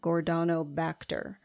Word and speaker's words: Gordonibacter: That's spelled GORDONIBACTER, Gordonobacter.